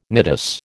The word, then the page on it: nidus: American English